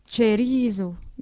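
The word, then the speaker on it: ĉerizo: C, avec un accent circonflexe, se prononce comme un T suivi d'un ch, cerizo, une cerise.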